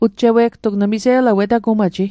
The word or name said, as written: ujjewigtug